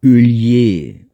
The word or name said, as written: Heuliez